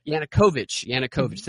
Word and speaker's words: Yanukovych: Yanukovych. – Yanukovych.